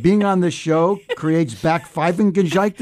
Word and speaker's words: Backpfeifengesicht: Backpfeifengesicht. – You want to hear that again? – If you can hear that without laughing. – Backpfeifengesicht. – Backpfeifengesicht.